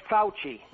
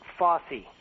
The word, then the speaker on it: Fauci: And it's Fauci, not Fossey. His name is Fauci.